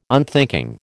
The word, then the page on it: unthinking: American English